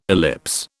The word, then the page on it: ellipse: British English